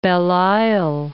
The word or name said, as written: Belle Isle, Strait of